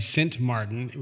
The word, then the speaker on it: Sint Maarten: And so the Dutch side is spelt, it's actually Sint Maarten, which is S-I-N-T-M-A-A-R-T-E-N.